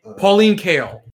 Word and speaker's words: Pauline Kael: Yeah, I don't know where it, because I think while I can appreciate certain aspects of it, I can appreciate the Was it Paula Kaline? I always get her name. Paula Klein? I always get her name wrong. Pauline Kael.